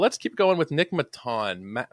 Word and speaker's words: Maton: Maton. M-A-Y-T-A-H-N is the pronunciation guide.